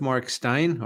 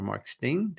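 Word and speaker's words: Steyn: GB News gets rid of Mark Stein, or Mark Steen, depending how you want to pronounce it.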